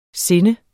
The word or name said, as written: sinde